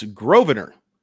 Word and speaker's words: Grosvenor: It's Grosvenor. Lucan Grosvenor right. Baker. And that's spelled Grosvenor. The S is silent.